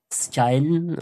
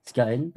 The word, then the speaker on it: Skagen: On arrive dans un petit village qui s'appelle Skagen, si je prononce bien en danois. Mmh. Skagen.